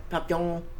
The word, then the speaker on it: Papillon: Papillon. – Something like that. Sure. – It's spelled like papillion, but I know it's probably not pronounced that way. Papillon.